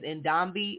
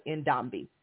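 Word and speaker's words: Ndambi: Her name is Ndombi. It's spelled N-D-A-M-B-I. N is in Nancy. D is in Dynamite. A-M-B-I. Ndombi.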